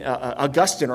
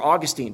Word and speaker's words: Augustine: And then it's found in Augustine or Augustine, depending on how pretentious you are in pronouncing his name.